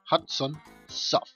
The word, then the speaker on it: Hudson Soft: El juego del Inspector Gadget de Super Nintendo era de la compañía Hands of Soft. en realidad nunca sabía cómo se pronuncia esa compañía. Hudson, Hudson Soft.